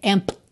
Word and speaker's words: empty: Você reparou que o P é completamente mudo, então você não tem que se preocupar em dizer empty. Empty, empty, empty, empty, tá? Pensa E-M-T-Y, tá? Empty.